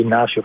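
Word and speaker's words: Ignacio: Λοιπόν, ο Ινάκη, Ιγνάσιο Πένια Σωτόρες, αν θυμάμαι καλά, το Ινάκη είναι το χαριδευτικό του, Ιγνάσιο είναι, ή Ινάσιο όπως προφέρετε, είναι 27 ετών.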